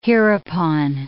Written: hereupon

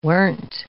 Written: weren't

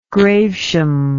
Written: gravesham

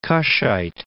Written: Cushite

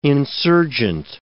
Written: insurgent

